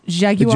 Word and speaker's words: Jaguar: Two officers had opened fire at O'Neill while he was still in the Jaguar, according to police. – It's pronounced Jaguar.